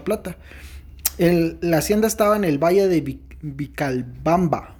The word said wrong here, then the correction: Vilcabamba: Vic Vilcabamba.